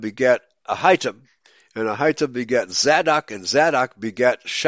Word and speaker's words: Zadok: Let's see how they pronounce this. Zadok. They put the emphasis on the second syllable.